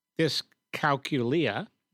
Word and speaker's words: dyscalculia: dyscalculia, did I pronounce mm-hmm.